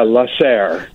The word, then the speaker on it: La Serre: How do you pronounce this? – Yeah, La Serre. Yeah. It means the greenhouse in French.